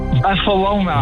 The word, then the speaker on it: Barcelona: Barcelona.